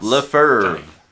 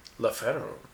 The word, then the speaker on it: LeFevre: Uh, our logo was created by Stacy, whose last name I didn't look up how to pronounce. – Lefer. – Lefer. That is more accurate than what I thought.